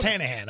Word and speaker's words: Tannehill: It's pronounced Tannehill, all right? Get it right.